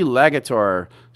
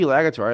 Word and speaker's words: Lagator: L-A-G-A-T-O-R. How do I pronounce that? Legator? – C.C. Legator. – C.C. Legator. So C.C. Legator.